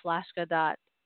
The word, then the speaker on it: Flaska: So you can go to flaska.us, which is spelled FLASKA, sort of like Alaska, but with an F, like as in Frank, .us.